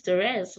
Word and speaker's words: Therese: There's no A at the end. – They adding shit now. – I've heard Therese, Therese. I've heard everything. Except, like, it's not that hard. My name is Therese.